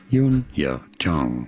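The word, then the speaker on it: Youn Yuh-jung: Pretty straightforward. Once you know, Yun-ye-jung.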